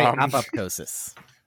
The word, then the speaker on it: apoptosis: I don't know if I'm pronouncing that right. They called it Brain X because they first pumped it through the brain's blood vessels in a pig. – I'm going to say apoptosis.